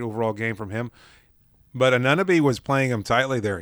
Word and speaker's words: Anunoby: I think OG Anunoby is how it's pronounced there. But I think I'm pretty sticking to that pretty closely. Either way, Anunoby, his size difference, his strength, his quickness was really hindering Bam early on, and I think Bam was eventually able to compensate.